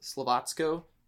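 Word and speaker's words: Slovácko: I want to say, give a shout out to Slovacko then, though, who I just realized are actually pronounced Slovacko, so that's fun.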